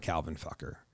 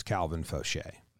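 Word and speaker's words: Faucher: The Rays threw a guy named Calvin Falker. – Okay. – Oh, it's not Calvin Fucker. It's Calvin Fauché.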